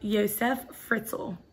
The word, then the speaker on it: Josef Fritzl: So anyways, this is about, I really don't know how to say his name, so I'm probably going to mess it up. But Josef Fritzl. – I think that's right.